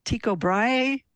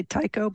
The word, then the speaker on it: Tycho Brahe: The brass nose, the one that I wrote about, belonged to Tycho Brahe. I think I'm saying it right. I used to say Tycho, but anyway, that dude, 1500s.